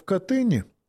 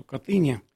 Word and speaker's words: Катынь: Итак, переходя к, собственно, вопросу Катыни, я всегда предпочитаю делать ударение именно так, как я сказал. Катынь – это произношение местное смоленское, и это польское произношение. Я не люблю это ударение по одной простой причине. Кат по-польски означает «палач» со всеми вытекающими...